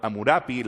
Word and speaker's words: Hammurapi: Menciono Hammurapi, la pronunciación con P es mejor aceptada recientemente que la B que ustedes están acostumbrados tal vez.